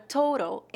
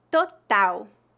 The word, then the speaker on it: total: Total, total, total. Note que a letra T antes das vogais A e O tem uma pronúncia forte e a letra A tem uma pronúncia bem aberta. Total, total.